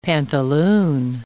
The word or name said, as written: pantaloon